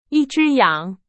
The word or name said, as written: 羊